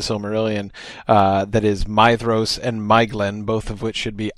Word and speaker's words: Maeglin: But anyway, Maeglin is the correct pronunciation.